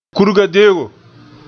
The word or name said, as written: kurga-deo